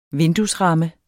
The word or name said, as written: vinduesramme